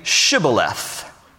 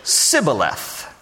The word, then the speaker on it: Shibboleth: And if he said no, then they would say to him, then say, Shibboleth. And he would say, Sibboleth. For he could not pronounce it right.